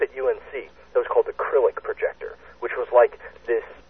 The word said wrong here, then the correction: Cyrillic: cylinder with all these Krillic letters, or I might be saying that wrong, the Russian letters. – Cyrillic. – Cyrillic, yes, the Cyrillic Projector.